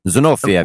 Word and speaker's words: Zinovieff: Zinof- Z- It's Russian. Zinovieff.